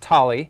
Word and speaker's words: Thali: It's pronounced Thali.